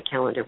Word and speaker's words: Callander: And then they have to put in either my last name or Chad's last name, because professionally I go by Calendar, which is spelled C-A-L-L-A-N-D-E-R.